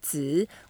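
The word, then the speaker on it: Zi (子): Wang Wang And to pronounce Zi, we can put the upper teeth and lower teeth together. Mm.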